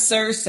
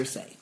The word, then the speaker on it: Circe: That was Circe. – Is that how they pronounced it? – That's the name of the enchantress that lured them there. – Okay. – Circe.